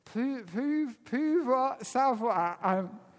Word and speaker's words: pouvoir savoir: And he called it pouvoir savoir. It's pronounced Frenchly, which is power knowledge.